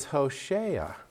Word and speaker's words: Hosea: And actually, they say it's Hosea.